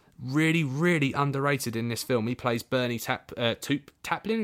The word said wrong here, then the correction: Taupin: Is that how you pronounce it? – Bernie Taupin. – Taupin, there we go, sorry.